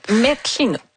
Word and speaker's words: Metchinut: Je ne sais pas si je prononce bien, mais c'est quelque chose comme Messinut. – Metchinut. – Ah, merci.